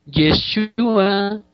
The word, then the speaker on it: Yeshua: Por eso es que en realidad ahora nosotros pronunciamos Yeshua en arameo y Yehoshua en hebreo, igual que el nombre de Josué, que le apareció aquí arriba.